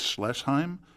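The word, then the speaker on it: Schleissheim: They shot it at, and I might be mispronouncing this German word here, but I think it's Schlesheim. – Yeah. – Schlesheim? – I think you're right.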